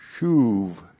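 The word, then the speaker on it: shuv (שׁוּב): and then the verb shuv. S-H, long U-B, shuv.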